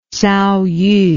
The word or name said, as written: tsaoyu